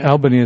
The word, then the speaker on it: Albany: Albany, as everybody reminded us to pronounce it,